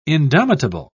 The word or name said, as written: indomitable